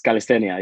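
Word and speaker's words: calistenia: because in Spain it's calisthenia, right? – Yeah, in Spanish it's calisthenia. Yeah, yeah.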